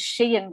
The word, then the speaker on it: Sheehan: My maiden name is Sheehan, which is Irish. – Oh, yes. – And it's spelled S-H-E-E-H-A-N.